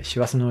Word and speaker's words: 師走: 師匠が走ると書いて、師走の時期に、